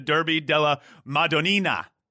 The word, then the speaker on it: Derby della Madonnina: Is there a derby you would consider to be similar to the Derby della Madonnina? – Wow, good pronunciation.